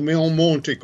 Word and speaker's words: Marisa Monte: Marisa Monte o Meo Monte, come la pronunciano i brasiliani, una delle protagoniste del tribalismo.